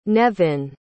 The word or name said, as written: Nevin